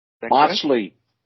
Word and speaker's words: Machli: So uh, help me with the pronunciation. Machli? – Machli. – Machli yes, that's what correct.